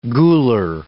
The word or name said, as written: gular